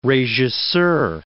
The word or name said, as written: regisseur